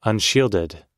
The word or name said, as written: unshielded